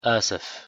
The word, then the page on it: desculpe: arabic